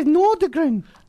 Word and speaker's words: Nordgren: Oh, it's the Swedish terminology. But in English, you pronounce it as Nordgren.